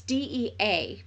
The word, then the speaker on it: Dea: It's D E A, but it's pronounced Dea.